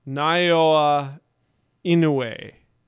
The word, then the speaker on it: Naoya Inoue: Naoya Inoue.